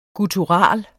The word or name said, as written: guttural